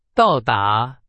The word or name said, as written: 到达